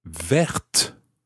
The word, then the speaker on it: verte: So we would say une voiture verte. Une voiture verte. And this time it's spelt V-E-R-T-E. So the E at the end makes the T sound. The T is no longer silent.